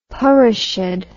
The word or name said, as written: parishad